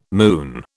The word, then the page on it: moon: British English